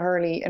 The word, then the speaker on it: hurl: Brian Cody, the man who, who told you the right way to say hurl or hurley, what do you think of him staying on? Well, I mean, anybody who knows that it's actually a hurl and not a hurley in my book is a good guy.